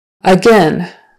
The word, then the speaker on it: again: Again, again, again, again. So it's either an eh or an eh. Typically when you're speaking quickly, it's again, again, with an eh, eh, again. Not again, even though gain is spelled G-A-I-N, and again is spelled with G-A-I-N at the end of the word.